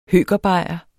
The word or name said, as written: høkerbajer